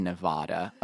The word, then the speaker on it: Nevada: I said Nevada, okay? That's how it's pronounced, where we planned to camp.